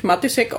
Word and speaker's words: Matisek: Ich spreche mich Matisek aus, aber eigentlich gibt es da so einen Hardcheck, also wo man das Matisek auch aussprechen kann, wie man will.